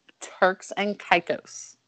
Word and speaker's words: Turks and Caicos: Turks and Caicos.